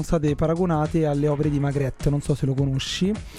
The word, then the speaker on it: Magritte: La pronuncia è Magritte, ne sono sicuro.